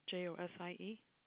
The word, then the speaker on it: Josie: Josie.